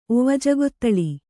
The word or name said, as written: ಒವಜಗೊತ್ತಳಿ